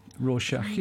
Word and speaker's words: Rorschach: Rorschach, yeah.